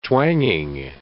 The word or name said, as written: twanging